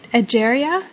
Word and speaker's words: Egeria: Egeria. Am I pronouncing that correctly? – Yeah, I love that.